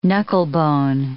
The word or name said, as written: knucklebone